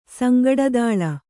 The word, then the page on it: ಸಂಗಟದಳೆ: kannada